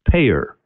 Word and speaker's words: PAEER: And actually, in the book, we've created a model, and the acronym for the model is PAEER, five letters, pronounced payer.